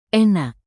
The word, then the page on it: enak: indonesian